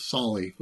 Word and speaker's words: Solly: In our lineage, his name is given as Grav Solomon Atheling, Sully, maybe better pronounced as Solly would be short for Solomon.